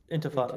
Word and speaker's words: intifada: And is it called infantad? I don't know how to Intifada. – Pronounce it. – Intifada.